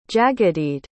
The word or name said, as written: jagadhidh